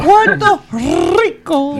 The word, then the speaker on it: Puerto Rico: Remember to roll your R's. So, Puerto Rico. – There you go.